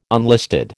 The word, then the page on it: unlisted: American English